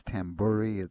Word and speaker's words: Tamburri: And the last name is Tamburi. It's spelled Tamburri. Tamburi.